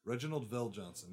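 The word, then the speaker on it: Reginald VelJohnson: That's how his name is pronounced. Reginald Vell Johnson.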